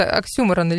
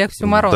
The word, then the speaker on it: оксюморон: То, чего не существует, оксюморон или оксюморон, да, ударение, да. – Верно. Да, давай, наверное, продолжим тему выборов, но все-таки включим отбивку и немножечко о других партиях поговорим.